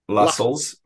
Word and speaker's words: Lassels: Lassels, rhymes with tassels, and treasurer of the Widow Sons Lodge.